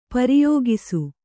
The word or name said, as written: ಪ್ರಯೋಗಿಸು